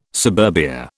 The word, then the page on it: suburbia: British English